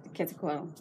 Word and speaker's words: Quetzalcoatl: Quetzalcoatl.